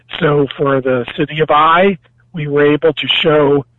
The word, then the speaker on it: Ai: You're talking about Jericho, you know, very famous places we've heard of, and maybe less famous ones like Ai or I. We pronounce it I.